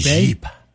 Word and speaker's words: Geep: I don't know if it's pronounced Jeep or Geep. It's G-E-E-P. – They're Canadian. – So it's probably Jeep. – Jeep.